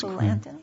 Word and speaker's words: Quillanton: So, I read that the case Quillanton, is that how you pronounce it? – That's Quillanton. Yes.